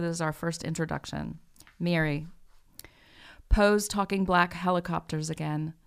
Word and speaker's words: Miri: Why don't you read, and it is pronounced Mary. – Mary. – Is spelled M-I-R-I.